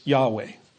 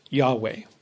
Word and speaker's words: Yahweh: And then later on in the passage, we see the actual name of God, which the best that we can pronounce it is Yahweh. That's probably the closest approximation, Yahweh.